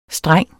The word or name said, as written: streng